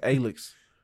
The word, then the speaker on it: Alyx: That's why you get Half-Life Alyx. – Why do you keep saying it like that? – Because it's spelled A-L-Y-X.